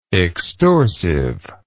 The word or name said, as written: extorsively